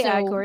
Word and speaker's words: Igor: That he kept getting dogs and kept naming them Igor. – You can say Igor. It's all right. It's the right way to say it.